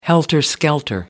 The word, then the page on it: helter skelter: American English